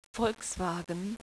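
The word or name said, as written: Volkswagen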